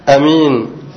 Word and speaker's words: أمين: والثالثة أمين بقصر الألف على وزن قدير وبصير، أمين، وهذه اللغة قليلة، هذه اللغة الثالثة ماذا يا إخواني؟